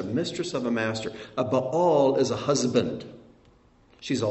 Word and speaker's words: Baal: This word ba'al is the word Baal. Baal. The pagan god, the Canaanite god Baal. It's pronounced the same way, even has the same vowel points. Ba'al is how it's pronounced.